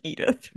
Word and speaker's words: Edythe: No, it's not spelled like Edith. It's spelled like, I mean, it might be pronounced Edith, but it's not spelled like that.